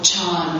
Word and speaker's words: Chan: which is was called Chan, which is the Chinese pronunciation of the word that's pronounced Zen in Japanese.